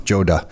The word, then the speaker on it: Joda: Am I pronouncing your last name properly? – Joda. Yes.